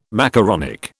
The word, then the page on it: macaronic: British English